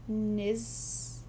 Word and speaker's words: knismolagnia: It's KNISMOLAGNIA, which I can only assume is nismolagnia.